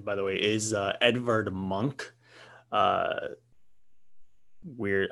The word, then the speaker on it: Munch: I know you wouldn't think there's a G in his last name, but it's M-U-N-C-H is how you spell it. And it's actually pronounced, I believe it is M-U-H-N-G-K is how it's pronounced.